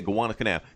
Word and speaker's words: Gowanus Canal: They find clues that it was in the Gowanus Canal. Am I saying it right? – Yeah. – Okay, Gowanus Canal.